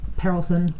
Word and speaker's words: Perelson: So Dr. Harold Pearlson was, and it's spelled P-E-R-E-L-S-O-N, so Pearlson. – Perlison? – Perlison?